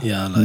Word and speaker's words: Y.A.L.A.: Yala, Yala schön langsam aussprechen.